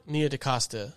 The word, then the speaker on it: Nia DaCosta: How you say it? – Nia DaCosta.